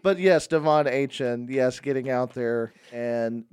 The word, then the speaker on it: Achane: Why do you keep saying Achane? – Because that's how he pronounces his name, sure.